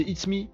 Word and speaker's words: itsme: Je pense que ça se prononce It's Me. Ah, c'est It's Me, I-T-S-M-E.